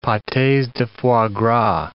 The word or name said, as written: pates de foie gras